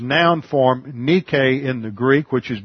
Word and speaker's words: nike: And it is, according to the system of pronunciation I was taught in Greek, it should be pronounced nike.